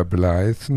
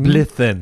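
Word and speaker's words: Blethyn: Und gucke den, weil ich den Namen, ähm, den ich nicht aussprechen kann, Brenda Blythen. – Blithen.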